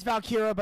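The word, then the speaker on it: Valkyria: Winner is Lyra Valkyrie. That's, hopefully I said that right. – It's Valkyra, but I've messed it up sometimes too. I was calling her Valkyrie for like the first couple weeks.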